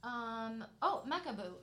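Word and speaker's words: Macabeu: Macabu. It's just spelled different. But in France they spell it and pronounce it Macabu.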